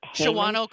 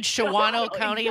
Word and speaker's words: Shawano: Shawano County? – Pardon? – I said Shawano County. – Exactly. – That's one of those Wisconsin pronunciations that only if you're from here, you know how to say it.